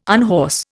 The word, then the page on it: unhorse: Indian